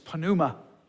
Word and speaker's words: pneuma: The Greek word for wind is pneuma. Yes, you do pronounce the P in this case.